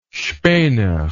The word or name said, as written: spener